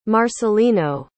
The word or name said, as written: marcelino